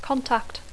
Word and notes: contact: English

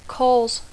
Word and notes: cause: English

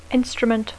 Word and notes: instrument: English

